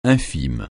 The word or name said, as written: infime